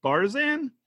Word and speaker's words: Barzan: So on Discovery, you play I'm going to pronounce this probably wrong, Barzan? – Yeah, that's right.